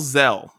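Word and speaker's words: Xxel: My last one is by Lil Zell. His name is spelled XXELL. I think that's how you say it, but it's Zell is how you say it. XXELL is Zizix. How you spell it.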